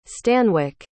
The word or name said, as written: stanwik